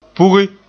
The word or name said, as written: pugi